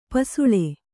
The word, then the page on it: ಪಸಲು: kannada